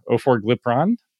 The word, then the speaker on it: Orforglipron: And so this study basically looked at this very specific non-peptide GLP-1 receptor agonist, Orforglipron. Am I missing a syllable in there? I feel like I might be. – I think you got it that time.